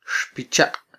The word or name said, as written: xpichaq